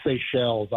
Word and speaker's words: Seychelles: Like, where is the Seychelles?